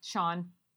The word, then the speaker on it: Siobhan: And you've also got a couple side characters, which is Siobhan, who's Anya's friend, Sean. I always say that weird because it's spelled wrong.